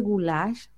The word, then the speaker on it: goulash: Así se pronuncia. Se escribe goulash, pero se pronuncia goulash.